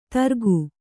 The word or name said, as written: ದರಕು